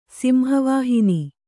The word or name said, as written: ಸಿಂಹವಾಹಿನಿ